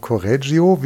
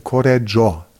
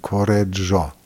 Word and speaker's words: Correggio: Der wird nicht ausgesprochen Correggio, wie wir das immer sagen als Deutsche, sondern wenn man Italiano richtig versuchen will, dann Correggio. Das heißt, das I müsste man so halb oder ganz verschlucken. Correggio.